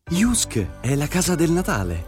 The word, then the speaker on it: JYSK: Jusk è a Parma, in via Nicola Morigi, uscita dell'autostrada Parma. Condizioni su jusk.it. Si scrive JYSK.